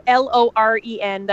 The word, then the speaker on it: Loren: it's at Radio Lauren, and my name is spelled with an O because my parents did that to me. So it's Radio Loren.